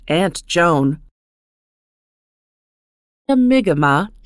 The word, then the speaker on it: Mi'kmaq: Mi'kmaq is pronounced Mi'kmaq. It is also spelled M-I-G-M-A-G, M-I-C-M-A-C, and M-I apostrophe K-M-A-Q.